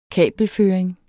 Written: kabelføring

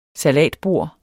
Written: salatbord

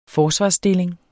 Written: forsvarsstilling

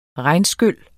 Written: regnskyl

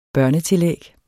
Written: børnetillæg

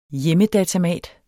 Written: HJEMMEDATAMAT

